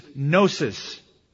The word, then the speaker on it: gnosis: And the word for knowledge in Greek is gnosis, or gnosis, if you wanted to mispronounce it. G-N-O-S-I-S, gnosis.